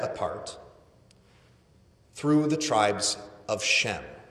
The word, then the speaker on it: Shem: In Hebrew, the letter can be both an S sound and an SH sound. And so Shem can also be pronounced Sem.